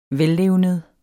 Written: vellevned

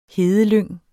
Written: hedelyng

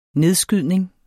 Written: nedskydning

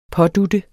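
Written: PÅDUT

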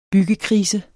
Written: byggekrise

